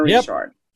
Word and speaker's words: Richard: Richard. – There you go. – It's spelled like Richard, but I'm a Richard.